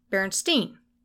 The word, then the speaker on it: Berenstain: Berenstain. With the last syllable pronounced Steen.